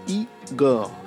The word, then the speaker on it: Igor: Ce n'est pas Bastard, ce n'est pas Goblin, ce n'est pas Wolf, ce n'est pas Cherry Bomb, ce n'est pas Flower Boy, c'est Igor, prononcé Igor.